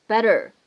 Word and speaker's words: better: 所以呢，在美式发音当中，这个单词呢，它读的是 better。